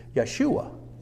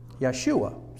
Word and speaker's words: Yeshua: The Hebrew pronunciation of it is Yeshua. Yeshua, see.